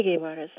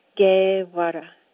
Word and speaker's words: Guevara: My last name is pronounced the same way you pronounce Che Guevara's last name, Guevara, Guevara. You kind of roll the R and pretend there's a D next to it, Guevara.